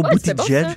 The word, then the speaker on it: Buttigieg: Euh, Buttigieg. Je ne sais jamais si je le prononce comme il faut, Buttigieg.